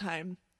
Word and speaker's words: Haim: And I actually think it's pronounced Haim. – Yeah, Haim. – It's supposed to rhyme with time, I think.